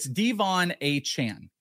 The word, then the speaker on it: Achane: In that Devon Achane? – It's actually, no, you told me Achane. Achane. I'm sorry. It's Devon Achane.